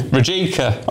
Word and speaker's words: Rijeka: Rijeka.